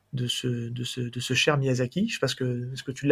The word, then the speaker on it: Miyazaki: Miyazaki, j'arrive pas à le prononcer. Miyazaki. – Miyazaki, merci.